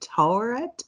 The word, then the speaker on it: Taweret: And that's I looked it up. Everyone's pronouncing it differently. It's can also be spelled T-A-U-R. So I think Taweret is like the closest.